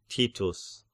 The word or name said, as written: Titus